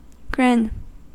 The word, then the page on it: grin: American English